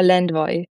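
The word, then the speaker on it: Lendvai: Kannst du das Ungarisch nochmal aussprechen? Weil ich weiß, dass ich nicht der Einzige bin, das falsch ausspricht. – Paul Lendwey, würde ich sagen. Lendwey, ja. Okay,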